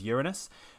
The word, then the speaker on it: Uranus: So uh, and I believe the pronunciation now is is accepted as Uranus, but it is uh, a very large gas giant uh, planet and also very icy.